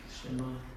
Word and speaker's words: Shem: Shema.